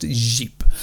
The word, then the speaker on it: Říp: That's well spelt R-I-P, Rip, but it's pronounced Jeep.